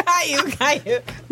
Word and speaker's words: Caillou: Did you say Caillou? Like yeah, Caillou.